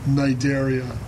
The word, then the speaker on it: Cnidaria: Cnidaria. You don't pronounce the C.